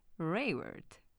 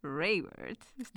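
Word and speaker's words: Raworth: Ja sitten sieltä tuli video, jossa oli Rayworth. Rayworth. – Joo. onko se "Rayward"?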